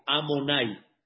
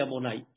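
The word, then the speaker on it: Adonai: ese no lo pronunciamos como está, porque se pronuncia de otra manera. ¿Cómo se pronuncia? Vean, aquí arriba, Amonai. Así se pronuncia. No se pronuncia como está escrito acá, si se pronuncia Amonai.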